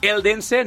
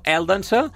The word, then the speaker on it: Eldense: li haurem d'ensenyar a pronunciar bé, sobretot els noms en castellà, eh? Eldense, no, Eldense, i Hèrcules.